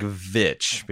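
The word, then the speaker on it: VVitch: It's more like Vitch because it's spelled with two V's. It's a really long, drawn-out V.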